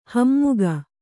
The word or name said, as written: ಹಮ್ಮುಗೆ